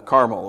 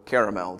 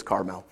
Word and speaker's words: Carmel: Saul had gone up to Carmel, or Caramel, depending on how you pronounce that. It's Carmel.